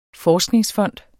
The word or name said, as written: forskningsfond